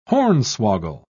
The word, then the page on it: hornswoggle: British English